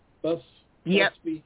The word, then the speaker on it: Busby: Busby. – Okay. – B-U-S-B-Y, that's me.